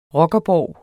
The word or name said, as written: ROCKERBORGE